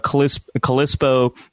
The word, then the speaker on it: Calypso: So Calypso, am I saying that right? Yes.